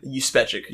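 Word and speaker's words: Juspeczyk: How do you pronounce it again? – Juspechic. – Juspechic. – Oh, that's how the motion comic pronounces it, which it sounds plausible. – Yeah, it's spelled with a J, pronounced with a Y.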